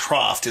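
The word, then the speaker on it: Kroft: Am I pronouncing his last name right? – I believe so. I think it's Croft is what it is, yeah.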